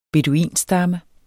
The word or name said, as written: beduinstamme